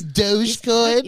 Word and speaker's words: Dogecoin: By pronouncing it that way it's, already. – It doesn't even matter. It's a doggy. He's Dogecoin.